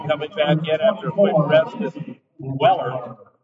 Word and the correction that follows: Weiler: Check that. That's Weiler. I thought it was, and I heard it pronounced Weller.